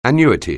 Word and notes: annuity: anglais

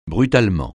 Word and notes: brutalement: francais

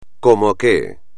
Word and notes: como que: espagnol

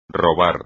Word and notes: robar: espagnol

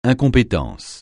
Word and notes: incompétence: francais

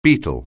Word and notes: beet: anglais

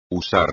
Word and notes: usar: espagnol

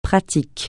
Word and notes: pratique: francais

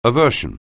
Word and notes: aversion: anglais

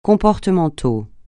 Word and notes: comportemental: francais

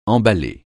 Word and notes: emballer: francais

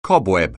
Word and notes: cobweb: anglais

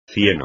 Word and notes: cieno: espagnol

